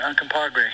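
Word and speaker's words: Uncompahgre: We pronounce it Uncompagre.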